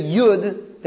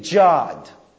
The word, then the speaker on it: Yud: For whatever reason, they think a Yud is pronounced as a J. So they say, instead of Yud, they say Jod.